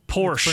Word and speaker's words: Porsche: Porsche. – It's pronounced Porsche.